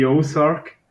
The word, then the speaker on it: Ozark: Ja, ich habe das Gefühl, dass die Serie mega viele Leute schon geschaut haben, hier wenn sie jetzt gleich einmal die Ozark.